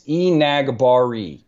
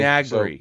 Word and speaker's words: Enagbare: Uh, no, according to I've heard like five different uh, pronunciations, but according to the South Carolina pronunciation guide that I have in front of me right now, it's Enagbare. – Okay, Enagbare, so, Kingsley so, Enagbare.